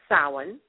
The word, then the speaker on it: Samhain: And sometimes you hear it mispronounced as Samhain, but it's pronounced Samhain.